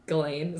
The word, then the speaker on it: Ghislaine: So this one reporter allegedly claims that he attracts Ghislaine, is that how you say it? Ghislaine's yeah.